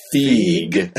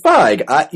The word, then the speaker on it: Feig: My number 7 is Bridesmaids, directed by Paul Feig. Although, if you're going to go with the Germanic pronunciation, it should be Feig. E before I is like Seinfeld, Feig, but it's probably Feig.